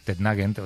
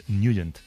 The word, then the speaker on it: Nugent: Hombre, siempre lo hemos llamado en el barrio Tednagent o Tednagen. – Claro, yo era de Tednagent. – Pero la pronunciación correcta es Nugent. – Te fuiste a Washington y descubriste que era Nugent.